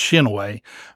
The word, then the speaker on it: Chinoe: But growing up here in Lexington, we've always known it as Chinoe. – Chinoe. – Again, my school bus went up Chinoe, up to Christ the King. So I always have known it as Chinoe. It's Chinoe.